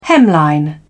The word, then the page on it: hemline: British English